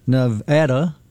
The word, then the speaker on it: Nevada: Because it played a central role in the presidential election, people learned that it is Nevada, not Nevada. – Yeah, Nevada. – Nevada. – Nevada. Yeah. Nevada.